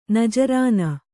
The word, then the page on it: ನಜರಾಣ: najarāṇa